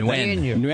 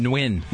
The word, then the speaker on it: Nguyen: Nguyen. – How do you say that? – Nguyen.